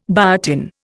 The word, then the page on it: biotin: Indian